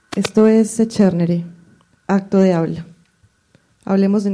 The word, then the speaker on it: Secernere: Apenas hace unos días averigüé la pronunciación correcta del nombre. Echernere es una palabra que viene del latín y significa separar. Se escribe así. – S. E. C. E. R. N. E. R. E.